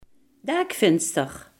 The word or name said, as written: dakraam